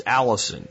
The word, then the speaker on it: allicin: So one of the big things garlic has, and it's why it has that smell, is a compound known as allicin. You pronounce it like a girl's name, but it's spelled A-L-L-I-C-I-N.